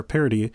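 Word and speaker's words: Paradis: or Parody, rather, that's how it's pronounced. It's French for paradise.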